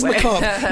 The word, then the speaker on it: macabre: It is macabre. But I realise that I only know the pronunciation of macabre because of the beginning of Texas Chainsaw Massacre. And he says macabre. – Oh, very good. – And it's not macabre, it's macabre because I looked it up on Google because I needed to use it.